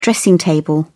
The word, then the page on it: dressing table: British English